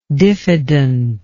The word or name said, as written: diffidentness